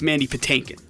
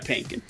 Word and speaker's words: Mandy Patinkin: It's Mandy Patinkin. – Oh. – Well. Not Pankin. But either way. – The T is silent. – It is not. I will turn to you, Jim.